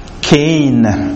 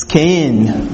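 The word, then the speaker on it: Cain (חן): The Hebrew equivalent is, and my pronunciation might be off here, it's Cain. C-H-E-N if you want to put it in an anglicized way. But it's Cain.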